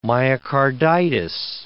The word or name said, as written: myocarditis